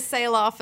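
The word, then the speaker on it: Sahloff: My last name is spelled really crazy. It's S-A-H-L-O-F-F. And the only way to get people to know how to pronounce that is to make your business a pun. So it turned into Sail Off Photography, as in sail off into the sunset and we'll make your magic happen.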